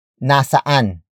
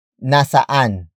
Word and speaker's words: nasaan: The word saan is the root word of another question word, and that is nasaan. Nasaan. Nasaan. Nasaan is spelled as N-A-S-A-A-N.